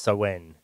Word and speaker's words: Samhain: It's Samhain or something. I think it's pronounced Samhain in the old. – Mm. Samhain.